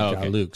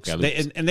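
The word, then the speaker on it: Gahluks: No, it's G A H L U K S. But that's the phonetic. – He didn't know how to spell it. I was like, Galuks.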